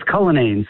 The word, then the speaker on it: Cullinane: It's Cullinane, C-U-L-L-I-N-A-N-E, Cullinane.